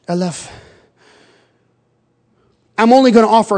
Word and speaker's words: aleph: And I'll just give you the first letter of the Hebrew alphabet, kind of, if you can't remember how to pronounce this, almost like an olive, aleph.